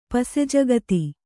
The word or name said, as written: ಪಸೆಜಗತಿ